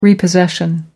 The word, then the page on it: repossession: American English